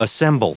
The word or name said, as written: assemble